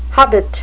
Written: hobbit

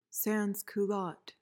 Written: sansculotte

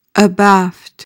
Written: abaft

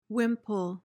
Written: wimple

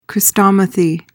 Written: chrestomathy

